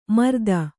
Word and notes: ಮರ್ದನ: mardana

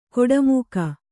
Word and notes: ಕಾಡುಮಿಗ: kannada

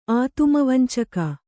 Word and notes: ಆತುಮವಂಚಕ: ātumavañcaka

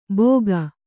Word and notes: ಭೋಗ: Kannada

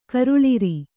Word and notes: ಕರುಳಿರಿ: karuḷiri

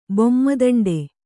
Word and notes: ಬೊಮ್ಮದಂಡೆ: bomma daṇḍe

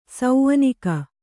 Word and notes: ಸೌನಿಕ: saunika

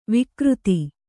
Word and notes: ವಕ್ರಿತ: Kannada

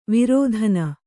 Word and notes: ವರ್ತನ: vartana

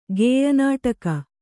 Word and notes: ಗೇಯನಾಟಕ: gēya nāṭaka

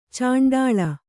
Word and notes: ಚಂಡಾಳ: caṇḍāḷa

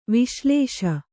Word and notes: ವಿಶ್ಲೇಷ: viślēṣa

